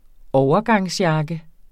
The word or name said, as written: overgangsjakke